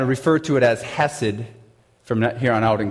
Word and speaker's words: chesed: C-H-E-S-E-D. Apparently, you're supposed to pronounce it hesed.